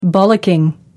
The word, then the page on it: bollocking: American English